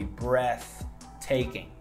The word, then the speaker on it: breathtaking: So the next word I have in here is breathtaking, breathtaking. Yeah, with a th sound. I know it's difficult to pronounce it, but you say breathtaking.